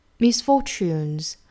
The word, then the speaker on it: misfortunes: 那它的音标啊，第一个音标应该是一个 y 的发音，那 t 和 y 当然在一起就要读成吃啦，misfortunes。 Misfortunes, misfortunes, we're told, are sent to test our fortitude.